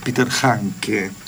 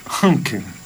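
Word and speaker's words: Handke: No es Peter Hanke. – ¿Cómo es? – Es Hanke.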